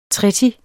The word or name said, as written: treti